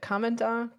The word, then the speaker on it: Commandant: Is it commandant of the Marine Corps? – Commandant. – Commandant.